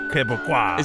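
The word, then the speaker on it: Québécois: That's how you say it. They're Quebecois.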